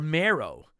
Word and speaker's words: Mario: And another, which you don't do this, but Mario turns into Mario or Mero.